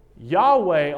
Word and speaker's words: Yahweh: But I think it's good to recognize that Jehovah, that was not the Hebrew pronunciation. That is a name that has been made up or contrived through language. Yahweh, on the other hand, in more recent times, it seems that Jewish scholars have agreed that that was probably the correct pronunciation of YHWH.